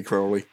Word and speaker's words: Crowley: I was up in an iron between Crowley or Crowley or... – Is it Crowley? – Is it Crowley. – Yeah, actually, all three work.